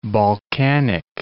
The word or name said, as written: Balkanic